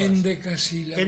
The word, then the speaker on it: endecasílabas: ¿Cómo lo llamaste? ¿Undecasílabas? – Endecasílabas. – Endecasílabas.